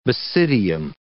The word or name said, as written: basidium